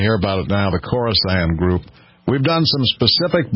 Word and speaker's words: Khorasan: K-H-O-R-A-S-A-N, Khorasan.